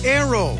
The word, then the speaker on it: Naoero: This program is previously called the country Nauru, N-A-U-R-U. The 11,000 residents have altered Nauru to be now Aero, N-A-O-E-R-O. They're going back to the pronunciation of their native language and losing a pronunciation used by outsiders.